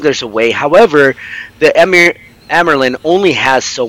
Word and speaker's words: Amyrlin: Once they're in Amyrlin, how do you guys pronounce it? – I say Amyrlin.